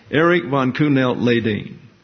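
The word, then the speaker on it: Erik von Kuehnelt-Leddihn: Q knelt, and he knelt like that. And then Leydin, like a dean of students, Leydin. I said, yes, sir, I have it. Eric von Kuhnelt-Leydin. So that's how you say his name, and tomorrow night we'll find out what he wrote.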